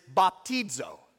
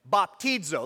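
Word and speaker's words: baptizo (βαπτίζω): And how you pronounce this Greek word is baptizo. Baptizo.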